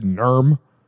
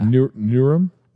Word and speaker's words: Nuremberg: Nuremberg. You said Nurm. – Yeah. – Nuremberg.